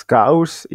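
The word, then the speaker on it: scouse: Lapskaus på liverpoolsk heter skaus, uttales skaus i en stavelse.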